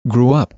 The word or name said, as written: grew up